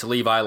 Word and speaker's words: Levi: and I was told it's Levi like the jeans.